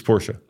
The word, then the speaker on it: Porsche: It's Porsche. – Okay, why is it Porsche? – Because in German, you pronounce all the letters.